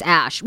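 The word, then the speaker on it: Æ: Because he gave the correct pronunciation that it's Ash, which I think actually listeners told us, but. – Yeah, I think our one of our famous listeners, Penny, told us the AE combined is Ash.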